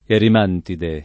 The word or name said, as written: erimantide